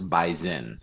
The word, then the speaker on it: bison: If you want to sound like you're from around the area, you will pronounce it with a Z, even though it's spelled with an S. Bison.